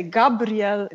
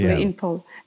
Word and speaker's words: Gabriel: You almost pronunciated it perfectly. You did. You said Marta, but if you would also say Gabriel, that would be perfectly Gabriel.